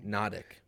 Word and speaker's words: Natick: I could be pronouncing that wrong. – Natick. – No. He's like, actually, it's Natick. It's like, man, how is anyone supposed to guess these Massachusetts towns?